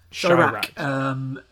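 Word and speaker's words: Chi-Raq: Chirac, as in to rhyme with Iraq about Chicago.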